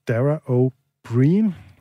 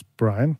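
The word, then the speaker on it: Dara Ó Briain: Nu skal vi til en irsk komiker, som vi ikke har spillet, øh, nemlig Dara O'Brien, som han faktisk udtales, selvom at det, man tror, at han udtales Brian. Øh, men det er øh, irsk og et eller andet magelisk og noget.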